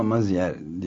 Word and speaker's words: Maizière: Ama Maizière diye okunuyor.